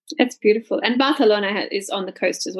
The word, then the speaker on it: Barcelona: And it's funny, like we say in English Barcelona, but obviously correct is Barcelona.